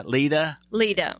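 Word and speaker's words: Leida: Eric and how do you pronounce her name? Lita? – Lita. Mm-hmm.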